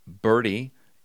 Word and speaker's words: Bertie: and one particular hospital I called on was spelled B-E-R-T-I-E. So you think about, how would you pronounce that? I went into the purchasing department just to nose around and ran into the purchasing director, and I said, ma'am, I have never been to Birdie County in my life.